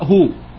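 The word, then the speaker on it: هو (ὁ): هو ثيوس، هو ثيوس، حرف الأوميكرون اللي هو الأول اللي في الأول ده، دي أداة التعريف ال، ماشي؟ أداة التعريف إيه؟ ال، وبتتنطق هو، أوميكرون عليه تنفس هاي، وبعدين ثيوس إله، حرف السيتا ثيتا وبعدين إفسلون وبعدين أوميكرون وبعدين سيجما، يبقى الإله ده اسمه مذكر، ده اسم إيه؟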